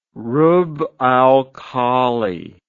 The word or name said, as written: Rub al Khali